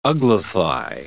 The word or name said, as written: uglify